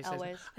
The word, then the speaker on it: Elwes: Yeah, Cary Elwes. Ways, yeah. Say, Elwes. – I always mispronounce his last name.